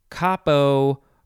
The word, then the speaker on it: capo: Okay, moving on to the second form, but this one is pronounced capo, or just capo. Either one of those, I think, is fine.